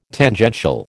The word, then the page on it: Tangential: American English